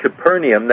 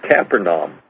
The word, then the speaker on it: Capernaum: Now this word Capernaum, that's pretty much the pronunciation here in the United States, but actually you go to Israel and it's pronounced Capernaum. So I may use either word, you know, Capernaum or Capernaum, and so that's probably the more correct pronunciation would be Capernaum,